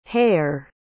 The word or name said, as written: hayer